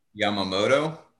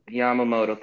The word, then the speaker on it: Yamamoto: Uh, Yamamoto. – Yamamoto.